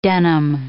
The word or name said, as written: denim